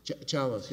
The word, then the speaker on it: Chalos: Is it pronounced Chaos? – Chalos, yes, sir. – Chalos. – I think there was a typo. They spelled my name C-H-A-I-O-S, but it's actually C-H-A-L-O-S.